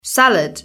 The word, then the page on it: salad: British English